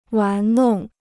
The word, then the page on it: 玩弄: wán nòng